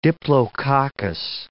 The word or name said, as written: diplococcus